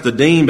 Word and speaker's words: Adino: That first name mentioned there, Adin, it's got an O on the end of it, but just pronounced Adin, but Adino, if you want to call that, was also known as Joshabim, or Jashabim.